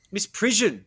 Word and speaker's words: misprision: Ooh. Is that it? Misprision. That sounds much more convincing. I've only seen this word and I've been like, how do you say that?